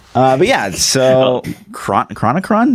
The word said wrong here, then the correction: Chronicon: am I saying that right? – Chronicon, yes.